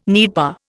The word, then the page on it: nipa: Indian